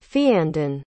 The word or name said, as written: feandan